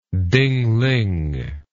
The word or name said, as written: dingling